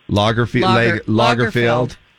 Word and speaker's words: Lagerfeld: Lagerfeld, and Coco Chanel.